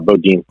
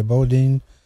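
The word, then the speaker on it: Boudin: how do you say that word? The sourdough bread guy? Bowden? – Bodine. – Okay, Bodine.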